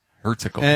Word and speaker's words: Herzegovina: By the way, again, Bosnia, Herzegovina. How did you pronounce that last part? – Bosnia and Herzegovina. – Herzegovina.